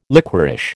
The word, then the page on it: liquorish: American English